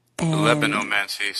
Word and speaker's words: Libanomancy: Libanomancy. Spelled like the word Lebanon, right? Libanomancy.